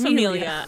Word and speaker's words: famiglia: the the famil- famil- famiglia, familia. I think it's familia. I think the G is silent.